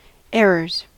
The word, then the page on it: errors: American English